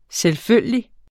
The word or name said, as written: selvfølgelig